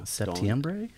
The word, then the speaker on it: septiembre: Septiembre? – Yeah.